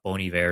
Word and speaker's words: Bon Iver: Although, oddly and funny enough, Bon Iver is going to be playing on the Sunday night.